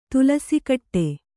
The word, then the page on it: ತುಲಸಿಕಟ್ಟೆ: tulasi kaṭṭe